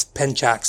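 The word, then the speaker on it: Pencak: Ever say his name clearly, but at the end, they I believe in the language it's Ucho, because the martial art is spelled Pensac, and it's Penchak, so I'm guessing the C makes a ch sound in Indonesian.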